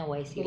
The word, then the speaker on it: YSEALI: Jadi tulisannya Y S E A L I. Jadi gue baca aja Y S I L E apa apa yang gitulah pokoknya ternyata bacanya Waisili.